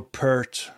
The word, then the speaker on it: Peart: And the thing I enjoyed most watching a Rush concert online or listening to an album was the drumming, of course, Neil Peart or Pert, however Peart. You say it. Yeah, there's different verse. Some say it one way and some say it the other way. But Peart, I think, is how you say it.